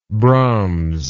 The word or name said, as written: brahmsite